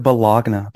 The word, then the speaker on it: bologna: bologna before I fuck with turkey, bitch. – Yeah, that's disgusting. Bologna. Anything where there's a silent G in that bitch, no, thank you.